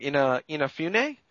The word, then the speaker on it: Inafune: Inafune, yes.